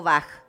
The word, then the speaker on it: Bach: ¿Se dice Bach o Bach? – Bach. Bach. ¿Por qué, algunos dicen ¿Por qué? – ¿Eh? Mira, se supone que la pronunciación del apellido de él era Bach.